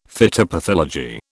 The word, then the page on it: phytopathology: British English